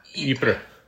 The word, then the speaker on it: Ypres: Ypres.